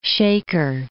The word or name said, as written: Shaker Heights